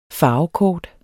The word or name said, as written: farvekort